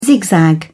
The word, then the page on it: zigzag: British English